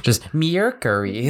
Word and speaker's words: Mercury: Just Mercury.